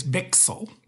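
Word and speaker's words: Bichsel: They pronounce it on TV as Bixel.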